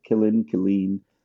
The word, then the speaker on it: Killeen: Killeen.